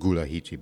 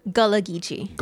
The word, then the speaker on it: Gullah Geechee: Now you said, and help me to pronounce that word because not many people, I might have said Gullahiche, but how do you say it? What is the correct pronunciation for that? Is it Gullahiche? – Gullah Geechee.